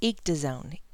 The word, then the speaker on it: ecdysone: Okay, I'm glad I paused and looked that up, because it says it's pronounced ectazone. Ectazone.